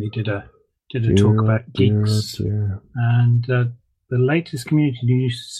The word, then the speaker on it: Guix: G-U-I-X. – Oh, Geeks. Yeah. Pronounced Geeks.